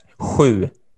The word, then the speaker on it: sju: Except for sju. Right. Yeah, that's hard. Can you guys say that? Sju.